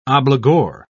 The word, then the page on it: obligor: British English